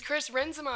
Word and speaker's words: Renzema: Chris Renzema? – It's Chris Renzema. I went to his concert, and he corrected us. – I'm sorry. – I know. I was like, I've been saying it wrong.